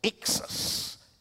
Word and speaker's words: ischys: This is the hardest word to pronounce. I-S-C-H-Y-S. Ixus. Ixus.